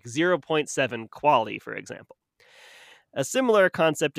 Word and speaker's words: QALY: QALY, pronounced QALY, stands for Quality Adjusted Life Year.